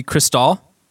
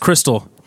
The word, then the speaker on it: Cristal: So number four would be Cristal. Am I sa- am I saying that right? you are. – Cristal. It's not crystal. It's not like Crystal Burgers.